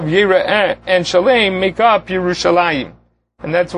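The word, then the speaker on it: Yerushalayim (ירושלים): And he notes that many places throughout Tanakh, almost exclusively, Yerushalayim is always written chaser. There is no yud in it. In a couple of places, it's pronounced even as a chaser. But in the majority of the places, it's almost like a Korean active that even though it's spelled chaser, we still pronounce it Yerushalayim.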